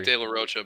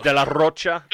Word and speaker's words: De La Rocha: It is Dak De La Rocha, by the way. – It is De La Rocha? Okay, I got the pronunciation.